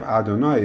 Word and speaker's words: Adonai: The idea of Adonai is the way we pronounce it.